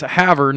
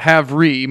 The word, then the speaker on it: Havre: And it's Havre, not Havre. Most people pronounce it Havre. It's spelled that way, but it's Havre. Like, here's a horse you can have her, Tanner.